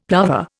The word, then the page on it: plover: Indian